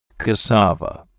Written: cassava

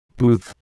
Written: booth

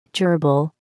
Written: gerbil

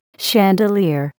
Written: chandelier

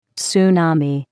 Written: tsunami

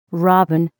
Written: robin

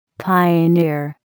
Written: pioneer

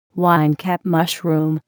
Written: winecap mushroom

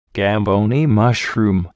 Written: gamboni mushroom

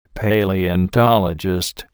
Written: paleontologist

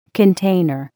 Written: container

